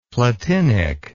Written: platinic

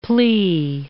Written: plea

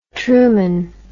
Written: trueman

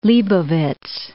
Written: Leibovitz

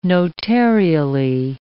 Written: notarially

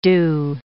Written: dew

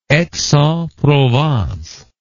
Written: Aix-en-Provence

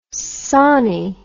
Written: sawney

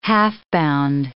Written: half-bound

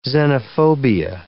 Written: xenophobia

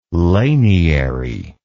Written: laniary

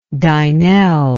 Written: dynel

